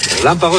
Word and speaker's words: relámpago: Relámpago es una palabra esdrújula. Y las esdrújulas se acentúan en la vocal de la antepenúltima sílaba.